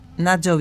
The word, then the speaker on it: Natxo: Y estamos aquí en Yo te leo a vos y creo que de Nayo Vidal no, no te había traído nada. Se escribe N de A T X O. Nacho Vidal.